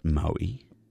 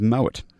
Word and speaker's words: Moët: I always called it Moët. – Moët. – And I'm reliably informed the correct pronunciation is Moët.